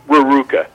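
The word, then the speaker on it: Rerucha: One of the central figures in the Matthew Shepard case, you can help me pronounce his last name, Cal, is it Raruca? – Raruca. Yes, Cal Raruca.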